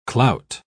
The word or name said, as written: clout